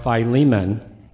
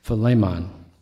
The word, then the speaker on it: Philemon: I've always heard it pronounced Philemon. And I can't tell you how many times I've taught it, pronouncing it incorrectly. And I looked it up yesterday in my Greek dictionary, and man, did I have egg on my face, because I realized I've been pronouncing it wrong, and I'd never even bothered looking it up. So it's Philemon.